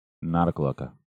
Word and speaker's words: Noctiluca: Noctiluca.